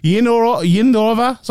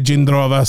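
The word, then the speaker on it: Jindrová: and they said her name, it wasn't Jindrov anyway, Yanova, like I cannot pronounce it like that, but Yindrova, something like that, it was like completely different to what I might say Jindrova, so, you know, we take the Irish and Czech together, we might get a good pronunciation in there somewhere.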